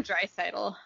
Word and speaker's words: Draisaitl: Leon Draisaitl. – Draisaitl.